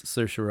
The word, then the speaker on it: Saoirse: That's how you know I'm a professional, because I know how to pronounce Saoirse Ronan's name.